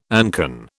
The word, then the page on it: ancon: British English